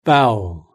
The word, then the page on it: bowel: British English